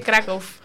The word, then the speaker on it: Krakow: ¿Se dice Cracovia o cómo se dice? – Se dice Krakow.